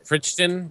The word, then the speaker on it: Crichton: It's pronounced Crichton, by the way.